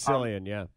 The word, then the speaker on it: Cillian: I think it's pronounced Cillian Murphy. – It is Cillian, Yeah, and I loved him in Peaky Blinders, by the way.